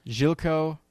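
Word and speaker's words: Željko: Zelko. Is it Zelko? – Zilko. Ivanek.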